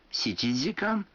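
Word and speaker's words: ситидикан (七時間): Некоторые цифры в этих случаях произносятся не так, как обычно. 4 часа – это йодикан, 7 часов – ситидикан, а 9 часов – кудикан.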